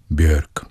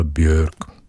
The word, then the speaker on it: Björk: Jako zjevení tenkrát působila debutová deska pojmenovaná příznačně Debut islandský zpěvačky a skladatelky a performerky Björk. Nevím, jestli se to vyslovuje takhle, ale já jsem ji vždycky prostě říkal Björk, tak jako švédsky, i když ona je z Islandu.